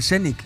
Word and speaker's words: Zenit: El Zenit, está bien dicho, sí, pronunciado.